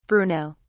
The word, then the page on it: bruno: British English